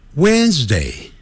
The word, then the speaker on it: Wednesday: 我们 It's 先不念， Wednesday。那个 WD 那个 D 不念出来的 When，更不要念成 Wednesday、哦、不是按照那个字母这样来念啊，不要自己发明叫 Wednesday，不是这样子。